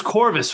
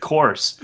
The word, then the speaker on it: Chorvs: Yeah, I thought it was Corvus for the longest time until someone said Chorus. And I'm like, well, that's a dumb way to spell Chorus. – It's like Chavirches. – So it's spelled, for people who don't know, it's spelled C-H-O-R-V-S. – Yeah. – So I don't know. I just said Corvus. – Yeah, it's like Chavirches. Have you ever seen Churches, which is C-H-V-R?